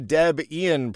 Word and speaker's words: Debian: So Debian is a combination of Deborah and Ian. The guy that made Debian was Ian. And his girlfriend at the time, who he later married, was Deborah. Deb Ian. And that's why Debian is named Debian.